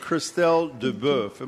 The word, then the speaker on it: Christelle Deboeuf: Christelle Deboeuf. Am I pronouncing that right, Christelle? – You are, yeah.